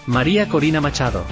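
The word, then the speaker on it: María Corina Machado: Maria Corina Machado.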